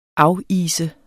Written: afise

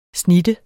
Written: snitte

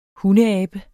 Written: hundeabe

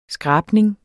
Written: skrabning

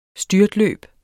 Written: styrtløb

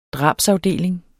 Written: drabsafdeling

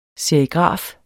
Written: serigraf

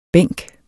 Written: bænk

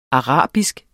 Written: ARABISKE

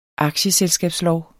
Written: aktieselskabsloven

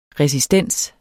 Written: resistens

